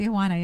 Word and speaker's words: Tijuana: That's how you say it. – Tijuana, yeah.